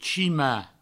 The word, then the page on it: chimar: chima; provençal